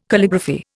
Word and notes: Calligraphy: Indian

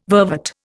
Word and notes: vervet: Indian

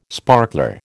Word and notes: sparkler: American English

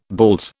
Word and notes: bald: British English

